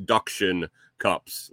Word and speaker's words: Duction Cups: Yes, it sounds like suction cups because it's spelled like suction cups. Instead, it's duction cups, like the animal quack quack.